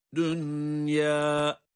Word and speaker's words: الدُّنْيَا: Bunlar izhar ile okunur. Çünkü burada cezimli nun ile ya harfi aynı kelimede gelmiştir. Bu tür yerlerde okuma izhar iledir. Yani ed-dünya şeklindedir. Onun için İdgam mal'unne yapmadık, yapmıyoruz. Okunuşu tekrar ediyorum. وَآثَرَ الْحَيَاةَ الدُّنْيَا İzhar ile okuduk.